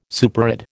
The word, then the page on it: superadd: American English